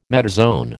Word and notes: metazoan: American English

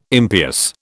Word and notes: impious: British English

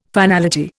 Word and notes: finality: Indian

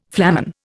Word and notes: flamen: Indian

